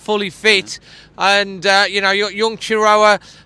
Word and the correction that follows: Chirewa: is that how I pronounce it properly? Chirewa, who's made his debut.